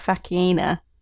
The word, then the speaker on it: Faecena: I always say Hispala Faecena. – Wow, there we go. Right, way off. – It's like a hard C.